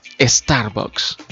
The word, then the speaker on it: Starbucks: Está también esa estrategia creativa que busco para dar a conocer pues mi producto, así como lo está haciendo en este caso Starbucks.